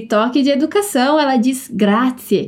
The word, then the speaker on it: Grazie: E a pronúncia parece com a pronúncia de um T e um S juntos. Então, repete comigo. Grazie. Grazie.